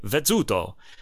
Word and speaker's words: Vezzuto: It's got a two Z's or two Z's if you're British. ZZ. Now it's easy, and that's pronounced like a TZ in English, right? Like pizza. So you'd say Vezzuto.